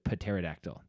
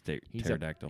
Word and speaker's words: pterodactyl: said pterodactyl. That's how he pronounced it. Yeah. So I don't know if people pronounce it like that. – No, it's just pterodactyl.